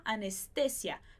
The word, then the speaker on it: anestesia: Então, em português a gente fala anestesia, mas em espanhol a gente fala la anestesia. La anestesia, perfeito? Se fosse anestesia em espanhol, a gente teria acento no i para indicar que aquele i realmente é a sílaba mais forte.